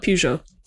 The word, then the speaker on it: Peugeot: And we had, and I don't know how to pronounce this, Peugeot. – It's French. – E-O-T. – Yes. – Peugeot. Nailed it.